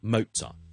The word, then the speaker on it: Mozart: Mozart，如果英文读法咧，我唔系 Mozart 嘅，我哋系 Mozart，Mo 停咗先嘅，T 音 Mo 跟住先至 sort 嘅，sort 你当 sort 咁咯，sort，所以 Mozart。至于本身可能德文嘅发音萧叔就唔识啦，或者其他欧洲语言嘅发音萧叔就唔识，但如果英文咧系读 Mozart。